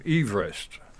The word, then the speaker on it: Everest: Mount Everest was named after a man who actually pronounced his name Everest.